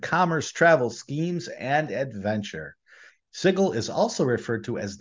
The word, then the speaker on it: Sigil: I have to say it correctly. Sigil, the city of doors.